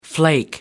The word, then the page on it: flake: American English